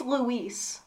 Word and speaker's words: Louis: His name was Lewis, but no one actually called him Lewis. – Nobody said the name. No, also, apparently it's Luis. – Luis. – Which, no feckin' way Luis is spelled L-O-U-I-S.